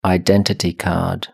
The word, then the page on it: identity card: British English